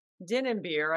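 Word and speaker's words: Dinnebier: But in the Missouri Valley, Katie Dinnenbeer, I think I said her name right. – You did.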